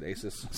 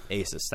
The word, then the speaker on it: Asus: I always said Asus. – Same. – Asus? Okay, because I've heard people in fucking reviews say Asus. – Yeah. Asus. – Asus, thank you.